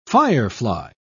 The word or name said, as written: firefly